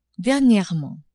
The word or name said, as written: dernierement